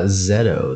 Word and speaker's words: Zeto: Zeto. – Zeto? – Z-E-T-O. Yeah. – Oh. – It's like either Ukrainian or Hungarian. I have no idea.